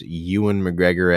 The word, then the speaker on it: Ewan McGregor: Anyway, my tip of the day is if you find one of your friends pronounce Ewan McGregor as anything other than Ewan McGregor, let them know.